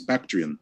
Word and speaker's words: Bactrian: This one coming in from the biologist bum says briefly putting politics aside, Afghanistan has some amazing wildlife, like the Bact- Bactrian deer. Am I saying that right? Yes, Bactrian.